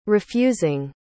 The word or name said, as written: refusing